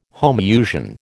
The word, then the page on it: homousian: American English